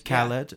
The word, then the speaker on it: Khaled: So I got a lot of flack last week for the last podcast we posted because I couldn't pronounce DJ Khaled. – Khaled. – Yeah. It's how you say it.